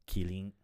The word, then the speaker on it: Kirin: Kirin.